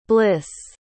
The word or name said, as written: blyss